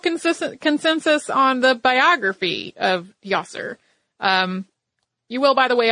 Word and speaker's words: Djoser: His name is spelled D-J-O-S-E-R, and you will hear it pronounced in a number of different ways. Us working with basically a dead language here, we have consulted many different sources, and we have gotten many different variations on this name from Djoser to Djoser to, you know, I mean, you could almost pick your pronunciation and someone out there in some field of expertise on it has used it. I think we're going to go with Djoser.